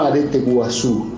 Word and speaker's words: arete guasu: Te cuento una estampa del arete guasu, del carnaval guaraní, del carnaval del Pimpín, así pronunciado, no arete guasu. Los guaraníes de Salta y de Jujuy, los guaraníes del sureste de Bolivia, dicen arete guasu, acentúan grave.